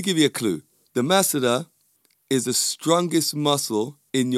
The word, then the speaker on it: masseter: And that muscle is called the masseter. Or depending on where you come from in the world, the masseter. Yes, spelled M-A-S-S-E-T-E-R. But I pronounce it the masseter.